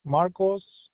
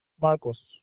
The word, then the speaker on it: Marcos: Marcos Aragon. Aragon. – Okay. Spell the first name, please. – Marcos. – How do you spell that? – M-A-R-C-O-S.